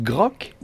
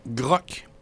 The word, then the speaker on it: Grok: L'autre, on le prononce Grok. – Grok.